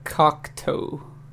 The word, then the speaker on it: Cocteau: But no, my 90s album is by a band called the Cocteau Twins. I even put the how to properly say this because I say it wrong all the time. Cocteau, like Cocteau.